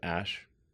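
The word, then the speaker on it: Æ: Um, and then the AE is like pronounced ash.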